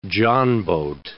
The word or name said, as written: johnboat